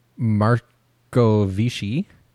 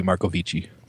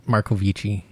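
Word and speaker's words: Marcovicci: It'd be Markovici. – Markovici, yes. I think I butchered her name last time, too.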